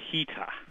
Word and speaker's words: Rita: Well, she just goes by Rita, R-I-T, Maria Rita, but it's pronounced Hita.